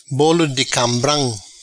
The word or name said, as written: Bolo di cambram